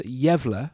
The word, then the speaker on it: Gävle: So this one takes place in a town called, it's actually pronounced Gävle, but it's spelled G-A-Y-L-E. Like, okay. Gale, but it's spelt Gävle, apparently.